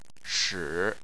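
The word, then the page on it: shi: tone 3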